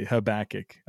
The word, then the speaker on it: Habakkuk: Habakkuk, actually, Habakkuk.